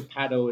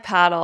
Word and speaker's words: padel: Yeah, so I'm just sticking to paddle. Is that what I should do? – I like paddle. I'm with you. I say it like you do.